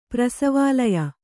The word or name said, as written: ಪ್ರಸವಾಲಯ